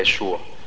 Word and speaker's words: يسوع: وكمان رئيس الملائكة جبريل قال لها إيه اسم المولود ده هيكون يسوع. اسم يسوع ده مهم قوي في عند اليهود العبرانيين، كان ليه دلالة كبيرة قوي اسم يسوع ده عندهم. يسوع يعني مخلص، وممكن يتنطق تاني حسب الحروف العبرية يشوع.